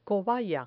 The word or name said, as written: cobaya